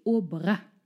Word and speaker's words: Aubrac: Heute sprechen wir über die wunderbare Lucie Aubrac. Liebe Katrin, wer war sie? – Übrigens, hinten das C spricht man mit.